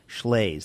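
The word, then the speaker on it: Shlaes: If you want to spell it, so do I. It's S-H-L-A-E-S, Schles.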